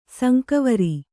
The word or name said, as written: ಸಂಕವರಿ